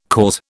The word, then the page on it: cause: British English